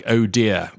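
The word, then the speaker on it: ODIHR: And they have the acronym ODHR, which they actually pronounce, it sounds like ODIA when they pronounce it, but they are the international monitors.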